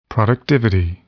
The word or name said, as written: productivity